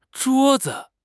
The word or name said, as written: zhuo1 zi5